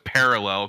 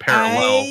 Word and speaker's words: parallel: And so, I've for my entire life, I've put the wrong syllable, the emphasis on the wrong syllable. I know it's parallel because my wife makes fun of me all the time now, but I've always said parallel.